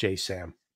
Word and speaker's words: JASSM: So the when you look at the ATACMS, the Storm Shadows, the Taurus, the JSAMs, you know, those are, a lot of people will say the JASSM, S, what is this, Joint Air-to-Surface Strike Missile, I think is the terminology, but the acronym pronounced JSAM.